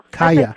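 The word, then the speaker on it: Kya: Which is what I was going to ask you about, the title, because that's what Tate, one of the characters, tells Kia. Is that how you pronounce her name, Kia? – Kaya.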